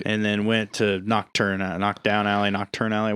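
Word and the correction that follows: Knockturn Alley: What is it? Knockdown Alley? – It's Nocturne Alley.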